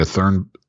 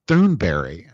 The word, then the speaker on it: Thunberg: I don't know if it's Thunberg, because Amy Goodman says Thunberry, but she's the only person that pronounces it. She says Berry, Thunberry, Thunberry. – I think the people who try to be Swedish say Thunberg, but I know, of course, we all know Berry.